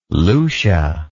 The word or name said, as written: Lucia